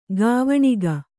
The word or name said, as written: ಗಾವಣಿಗ